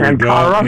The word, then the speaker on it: Ankara: A-N-K-A-R-A. All right, there Ankara.